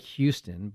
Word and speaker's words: Houston: It's spelled like Houston, but it's pronounced Houston.